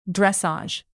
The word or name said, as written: dressage